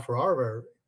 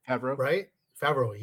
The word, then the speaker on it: Favreau: And I'm very disappointed, because John Favreau, I know I said the name wrong. He wrote it, Favreau.